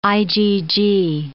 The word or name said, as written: IgG